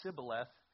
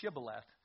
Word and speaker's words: Shibboleth: So they'd know when they said the word Sibboleth, it wasn't Shibboleth.